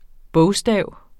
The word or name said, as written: bogstav